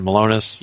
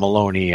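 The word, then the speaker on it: Milonas: Malonis. This is how you pronounce his name, right? Malonis? – Yeah, Brian Malonis, yeah. – Okay, so make sure it wasn't Maloney. I don't know why I keep wanting to say Maloney.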